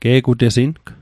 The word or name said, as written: ge'gutesing